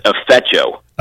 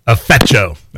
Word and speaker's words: Afecho: Yeah, it's Afecho. – Afecho. All right. – It's like the A's not there. Like I said Afecho.